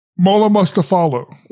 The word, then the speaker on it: Mollamustafaoglu: Mollamustafaoglu.